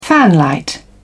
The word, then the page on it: fanlight: British English